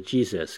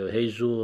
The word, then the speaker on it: Jesus: 佢不過佢讀出嚟叫 Jesus 啊嘛，個 J 讀咗個 H 音啊，但係 Jesus 咧就更加似耶穌基督原本嗰個發音啊，耶穌基督希臘文嘅發音就 Jesus 就係叫做 Jesus 啊，係 Jesus 啊。